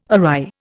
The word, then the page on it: awry: Indian